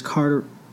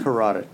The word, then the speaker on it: carotid: So he killed Miller by slashing his carotid. How do you say that? You know how to say that? – Carotid. – Carotid, good one.